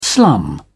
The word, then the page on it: slum: British English